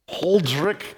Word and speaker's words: Huldrych: I call him Ulrich because the other way that you pronounce it is too hard for me. Huldrych, we'll just say Ulrich because it's easier.